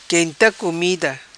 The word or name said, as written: quenta comida